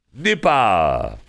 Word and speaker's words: Nipah: Nipah. How about Nipah you? Okay. Nipah you. Is that how you say it? N-I-P-A-H? Yeah, that's right.